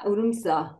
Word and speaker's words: Odense: It's in central, in near Odense. It's spelled Odense.